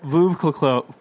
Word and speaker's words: Veuve Clicquot: Veuve Clicquot. What is that? – Veuve Clicquot.